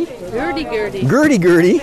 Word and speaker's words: hurdy-gurdy: Drums and guitars, and these are violins, or it's a Gurdy. – Hurdy-gurdy. – Hurdy-gurdy. – Yes. Perfect pronunciation.